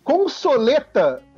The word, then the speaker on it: consoleta: É, consoleta tem, tem o guia que é com E fechado.